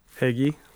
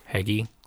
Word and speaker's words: Heagy: One guy, Ryland, I can't pronounce his last name, H-E-A-G-Y. – Heggie. – Heggie. So Ryland Heggie, yeah.